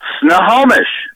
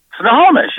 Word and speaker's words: Snohomish: You're on a Christian Car Guy show, and the first question is, how do you pronounce the name of that city? – Snohomish. – Snohomish? – Snohomish, yeah.